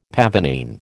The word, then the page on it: pavonine: American English